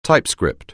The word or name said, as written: typescript